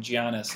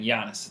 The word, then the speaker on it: Giannis: And we won't shame Clay too much for saying Giannis instead of just saying Giannis. It's a silent G, everyone.